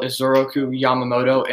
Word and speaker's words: Isoroku Yamamoto: The battle was planned by Admiral Isoroku Yamamoto in a response to the Doolittle raid over Tokyo.